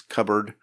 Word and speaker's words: cupboard: They're pronouncing the P instead of it being silent. I mean, you don't really pronounce the P in that word. It's cupboard.